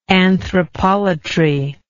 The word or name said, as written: anthropolatry